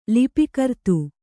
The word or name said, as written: ಲಿಪಿಕರ್ತು